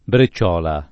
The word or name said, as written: brecciola